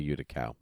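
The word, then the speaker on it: Yuda Kal: It's spelled Y-U-D-A space K-A-L. Utical. – Utical. I'd say Utical. Okay. – Utical. Yeah. Yeah. Like the Cal is spelled like Cal-El.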